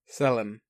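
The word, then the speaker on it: Sellen: It's S-E-L-L-E-N. – Selen. – It still sounds weird. Not your pronunciation is correct.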